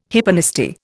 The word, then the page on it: hyponasty: Indian